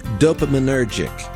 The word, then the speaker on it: dopaminergic: Dopaminergic.